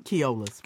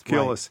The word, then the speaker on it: Keolis: Keolis, how you pronounce it? – Keolis. – Keolis.